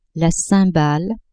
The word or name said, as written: cymbale